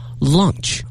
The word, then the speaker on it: launch: 准备推出哈，推出呢用的词呢是 launch L-A-U-N-C-H，这个推出呢不要跟 lunch 弄混了哈哈，大家看这个词说 lunch 和 launch 发音不一样，拼写不一样，意思呢更不一样啊！